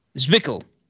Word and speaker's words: Zwickel: So, I've got Zwilk. – Zwickel. – Sorry, I wasn't sure how to pronounce the L in that situation.